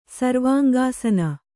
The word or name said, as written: ಸರ್ವಾಂಗಾಸನ